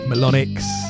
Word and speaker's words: MELONYX: And I did an interview with MELONYX, let me pronounce that correctly, MELONYX, and that's up on the full interviews up on the Tru Thoughts YouTube channel.